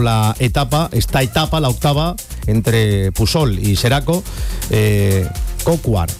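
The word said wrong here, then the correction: Coquard: seguramente no se dice así, pido disculpas, el ciclista francés. – Brian Kokar, que es precisamente el que está hablando, que imagino que no sé si se le entiende o no.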